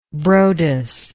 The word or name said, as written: Broadus